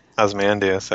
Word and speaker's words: Ozymandias: Ozymandias. I can never pronounce it. Ozymandias. – yeah. Ozymandias. I think that's yeah, that's good.